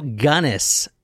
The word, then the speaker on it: Gunness: This is the Hell's Princess, known as Belle Gunness, G-U-N-N-E-S-S. And from everyone, because I actually looked up how to pronounce her name, and that's what everyone else says her name is. So I guess that's it. Gunness.